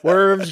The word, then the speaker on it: Worms: And in the year 1521, in the German city of Worms, it's spelled Worms, but that's not how it's pronounced. – Worms, Germany.